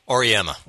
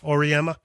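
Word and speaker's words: Auriemma: And he paid homage to, I believe, Jim Calhoun and Gino, or how do you say Gino's last name? Auremia? – Auremia. – Auremia.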